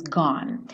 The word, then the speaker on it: gone: I can see clearly now the rain is gone. Essa primeira frase você vê que não tem quase anotação nenhuma, só o E do gone que não é pronunciado.